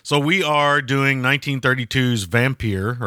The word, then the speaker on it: Vampyr: And it came back as Vampyr.